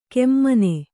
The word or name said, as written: ಕೆಮ್ಮನೆ